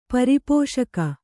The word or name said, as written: ಪರಿಪೋಷಕ